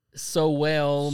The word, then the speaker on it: Sowell: I might be saying it wrong, but it's S-O-W-E-L-L. – Swole? – Sowell, maybe. – Yeah, Sowell.